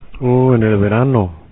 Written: en verano

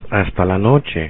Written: hasta la noche

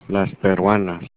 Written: peruanas

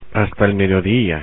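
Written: hasta el mediodía

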